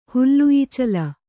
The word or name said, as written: ಹುಲ್ಲುಈಚಲ